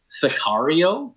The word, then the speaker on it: Sicario: Sicario. Am I saying that right? – Yeah. – Yes.